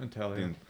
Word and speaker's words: Intaglio: The this might be out of order in terms of, you know, the timeline, but the, I hope I'm saying this right, Intaglio? – Yep, Intaglio.